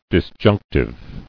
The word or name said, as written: Disjunctively